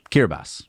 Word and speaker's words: Kiribati: Kiribati. No, it's pronounced Kiribati. – What, that's fun. The T is pronounced with an S? – I don't know why.